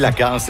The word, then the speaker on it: Lacasse: Lacasse, c'est Chloé Lacasse, c'est comme ça qu'on prononce son nom aux États-Unis, joueuse de soccer élite originaire de Sudbury, qui va continuer d'émerveiller les descripteurs de l'Utah pour les prochaines saisons.